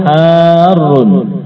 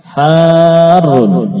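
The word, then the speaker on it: harrun (حارٌّ): Al-labanu harrun. Harun, ya, demikian cara membacanya dengan memanjangkan ha lalu mentasydid ra.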